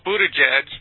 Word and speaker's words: Buttigieg: And I've researched how to pronounce his name, and I've heard a lot of pronunciations, but I heard him actually call himself Buttigieg, and so I'm trying to use that particular pronunciation of his last name, which is a hard name.